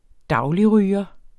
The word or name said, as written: dagligryger